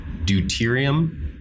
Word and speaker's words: deuterium: You mentioned, and I'm going to say this wrong probably, deuterium? – Yeah.